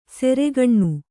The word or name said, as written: ಸೆರೆಗಣ್ಣು